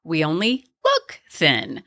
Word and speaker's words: We Only Look Thin: A lovely woman emailed and said that she notices that I put the emphasis on the wrong part of We Only Look Thin, because it is We Only Look Thin, which Donald was really good, or has been really good about saying.